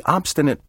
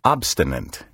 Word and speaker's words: obstinate: Obstinate is sometimes mispronounced obstinant, as if it were spelled O-B-S-T-I-N-A-N-T. Take care to spell and pronounce this word correctly. Obstinate. O-B-S-T-I-N-A-T-E.